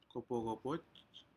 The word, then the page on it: copojcopoj: Aneityum